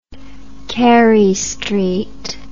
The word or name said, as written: Carey Street